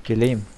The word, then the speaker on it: Guillaume: Uh, eh, Guillaume? Guillaume? Guillaume? Ja, i alla fall. Lattendress och uh, Gilbert Brulé ska vara med på Phoenix Camp. Hur, alltså hur uttalar man hans förnamn? Gulim.